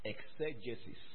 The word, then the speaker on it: exegesis: It's pronounced exegesis. Exegesis.